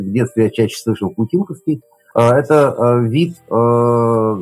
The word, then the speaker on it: Путинковский: А вот здесь, опять же, ударение а, в наши дни принято ставить Путинковский.